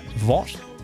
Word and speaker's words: Vogt: I'm going to say Vogt. V-O-G-T. How would you pronounce that? Vogt? – Vogt, yeah.